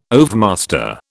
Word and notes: overmaster: British English

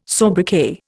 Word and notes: sobriquet: Indian

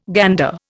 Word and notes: gander: Indian

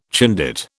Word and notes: Chindit: British English

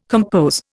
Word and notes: compose: Indian

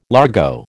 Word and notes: largo: American English

